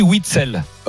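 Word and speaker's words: Witsel: Je le donne à Eric, mais on dit Witzel. – Ah non, on dit wagon et on dit Witzel.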